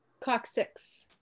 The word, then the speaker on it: coccyx: And then all the way at the bottom, you have the coccyx. Is that how it's pronounced? Anybody know? Coccyx.